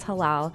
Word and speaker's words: halal: It's halal.